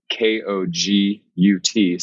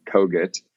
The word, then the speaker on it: Kogut: It's spelled K O G U T. So it's at Ben Kogut. All the socials, I, I locked down at Ben Kogut.